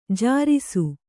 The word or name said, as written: ಜೇರೈಸು